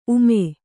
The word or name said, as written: ಊಮ